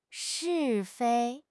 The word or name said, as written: 是非